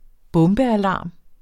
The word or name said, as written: bombealarm